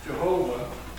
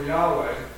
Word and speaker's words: Yahweh: Some pronounce it Jehovah, but it's really more Yahweh.